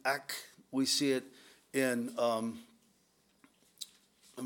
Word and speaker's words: ak: We don't see it in the English translation, but in the Hebrew translation, there is a Hebrew word, and we would pronounce it ak, A-K.